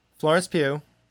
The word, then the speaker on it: Florence Pugh: Florence Pugh. – Is that how you say her name? – Yes.